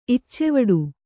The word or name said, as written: ಇಚ್ಚೆವಡು